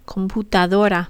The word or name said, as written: computadora